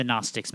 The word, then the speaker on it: Gnostics: Yes, we all love the Gnostics, mainly because it's like gnat. It's spelled wrong. It's got a G on the front of it, and that's just because we've got nothing better to do, so...